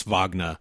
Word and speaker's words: Wagner: Michigan center Moritz Wagner. And yes, I'm going with the German pronunciation.